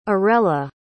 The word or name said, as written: orella